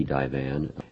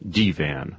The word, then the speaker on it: divan: I recall the turkey divan. I guess it can be chicken divan. – Trish Nixon's recipe was definitely chicken divan, or to use the French pronunciation, divan.